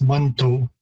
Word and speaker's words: Manitou: The word is typically pronounced Manitou. So there's a number of different shifts again in terms of pronunciation from the spelling.